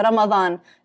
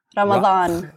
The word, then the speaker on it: Ramadan: Yeah, Ramadan. It's the D, you can say it like a TH. – Ramadan. – Like Ramathon.